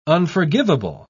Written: unforgivable

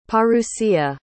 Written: parousia

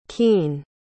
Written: keene

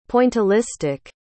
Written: pointillistic

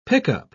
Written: pickup

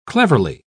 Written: cleverly

